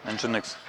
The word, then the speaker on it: Nginx: Правильно, как произносить Nginx? – Так, нет, Nginx, это правильно.